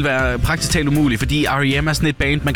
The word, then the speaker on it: R.E.M.: Altså, der er jeg jo så glad for at kunne hjælpe dig, fordi begge dele er egentlig rigtigt, men det er R.E.M., der er det rigtige svar.